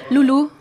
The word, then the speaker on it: Lolou: Hvem er Lulu? Er det rigtigt udtalt? – Lulu, faktisk.